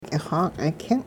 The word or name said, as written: k'ixa'king